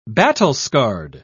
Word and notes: battle-scarred: British English

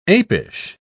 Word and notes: apish: British English

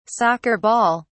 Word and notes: soccer ball: British English

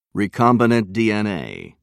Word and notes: recombinant dna: American English

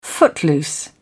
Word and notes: footloose: British English

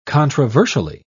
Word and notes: controversially: British English

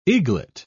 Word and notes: eaglet: British English

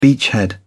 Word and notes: beachhead: British English

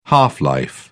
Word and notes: half-life: British English

